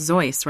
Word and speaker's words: Seuss: But it was originally, it's supposed to be pronounced Zeus, right? – Yes, it would have been pronounced Zeus originally, and that's the way the family pronounced it. It's a Bavarian name.